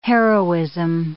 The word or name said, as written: heroism